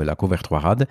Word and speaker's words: La Couvertoirade: Zapiszę wam to, długo się uczyłem tej wymowy, La Couvertoirade Fantastyczne miasteczko, obmurowane wielkim murem z XV wieku, a samo miasteczko z XII wieku.